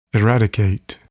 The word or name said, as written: eradicate